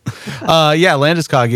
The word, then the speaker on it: Landeskog: What do you think about is it pronounced Landeskog? – I believe it's Landeskog.